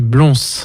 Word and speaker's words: Blons: C'est Blons. – Charlie Blons, B-L-O-N-S,